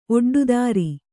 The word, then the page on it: ಒಡ್ಡುದಾರಿ: oḍḍudāri